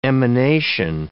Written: emanation

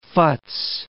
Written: futz

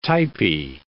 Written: typey

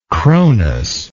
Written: cronian

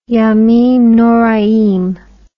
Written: yamim noraim

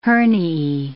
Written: herniae

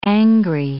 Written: angry

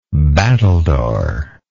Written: battledore

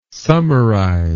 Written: summerize

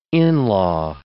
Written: in-law

